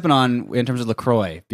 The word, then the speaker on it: LaCroix: You know, like, obviously it's pronounced LaCroix.